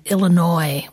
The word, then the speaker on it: Illinois: I remember making a face when someone pronounced the S on the end of Illinois when I was a child. And that person did learn how to pronounce Illinois correctly, but he never spoke to me for the next 5 years.